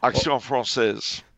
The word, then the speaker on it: Action Française: Was Action France wrong about the Dreyfus affair? – Action Française.